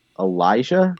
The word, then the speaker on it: Elijah: Is it Elijah? – Yep. There you go. That's correct.